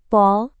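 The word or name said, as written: polda metro jaya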